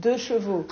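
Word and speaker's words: deux-chevaux: 2-chevaux.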